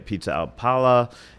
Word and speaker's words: pizza al pala: one of the great places to get pizza al pala in Las Vegas. Am I saying that right? Is the yeah. – Roman style that you do? – Yeah.